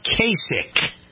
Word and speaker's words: Kasich: All right, it's pronounced Kasich.